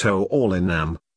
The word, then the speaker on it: Hurlingham: The town of Hurlingham, pronounced Ho-Orlinam, today has 60,000 residents, was founded in 1888 by Anglo-Argentines and got its name from the homonymous London Sports Club.